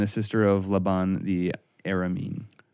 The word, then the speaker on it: Aramean: From but you can pronounce it like Aramean from today, though. It's spelled different. – That's Arminian. – Right. – No, this is Aramean. It's spelled like A-R-A. Okay. So it was Ara.